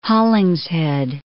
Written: Hollingshead

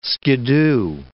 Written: skidoo

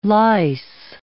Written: lyse

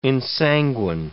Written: ensanguine

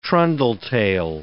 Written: trundle-tail

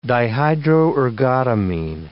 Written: dihydroergotamine

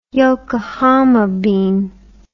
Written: yokohama bean

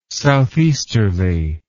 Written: southeasterly

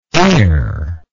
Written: airlike

